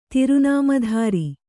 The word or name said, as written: ತಿರುನಾಮಧಾರಿ